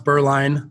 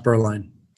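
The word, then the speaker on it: Beuerlein: First name is Tyler, T-Y-L-E-R. Last name is pronounced Berline, a little more difficult to spell. So it's B as in boy, E-U-E-R-L-E-I-N. And again, that's pronounced Berline. – And Hyper is H-Y-P-U-R.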